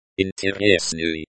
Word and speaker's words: интересный: Pronunciation is like интересный.